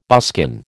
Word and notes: buskin: American English

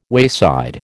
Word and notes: wayside: American English

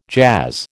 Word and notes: jazz: American English

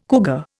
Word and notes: cougar: Indian

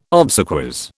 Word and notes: obsequies: British English

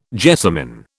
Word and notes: jessamin: British English

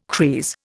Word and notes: creese: Indian

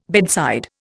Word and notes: bedside: Indian

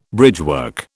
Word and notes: bridgework: British English

